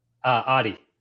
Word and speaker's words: Otte: How do you pronounce that correctly? – Otte. – Otte. – Yeah.